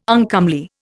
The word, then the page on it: uncomely: Indian